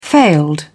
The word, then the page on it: failed: British English